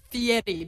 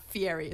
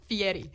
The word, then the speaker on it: Fieri: You guys started talking about Guy Fieri, who is an icon of mine. I love Guy Fieri. And it's Fieri, by the way, not Fieri. It's Fieri.